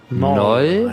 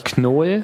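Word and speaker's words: Knol: Noll. Heißt es Noll. Aber oder ich, Knoll. Ich finde, man sollte das Deutsch aussprechen, wenn man auf Deutsch spricht.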